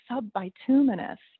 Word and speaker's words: subbituminous: Subbituminous, you know, it like rhymes with luminous, but it's not at all.